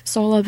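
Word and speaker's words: Tsolov: People, I feel like there's a lot of talk about, forgive me if I'm pronouncing this wrong, Nikola Solov? – Yeah, Solov. Coming up.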